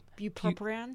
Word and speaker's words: bupropion: Bu- bupropion.